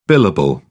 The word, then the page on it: billable: British English